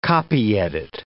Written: copyedit

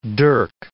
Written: dirk

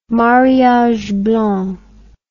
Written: mariage blanc

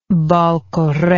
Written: baal kore

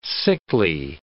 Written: sickly

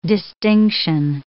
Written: distinction

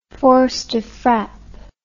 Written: force de frappe

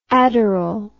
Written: adurol

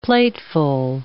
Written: plateful

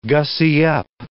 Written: gussy up

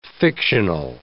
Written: fictional

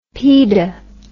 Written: pide

